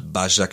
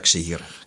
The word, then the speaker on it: Başakşehir: Bajak Başakşehir.